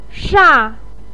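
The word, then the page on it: sha: tone 4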